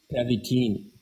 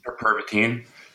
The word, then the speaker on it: Pervitin: When the economy in Berlin picked up, weren't a lot of workers using Pervitin, right? Am I saying that correctly? – Pervitin. – Pervitin.